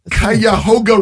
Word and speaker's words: Cuyahoga: Western North Carolina, Minnesota, Central Wisconsin, the Cuyahoga River Valley of Ohio.